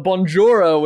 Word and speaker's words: Bonjoro: Bonjoro is very often mispronounced, as you said. And I think it's the bonjour that pulls people a lot. But Bonjoro, which is Bonjoro, is basically a personal video tool as well as a video testimonial gathering tool.